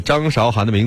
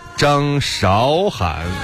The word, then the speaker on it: 张韶涵: 有没有觉得这个张韶涵的名字听起来有点奇怪哈？大家可能是不是都习惯叫她张韶涵呢？其实她的名字正确的读音应该是张韶涵。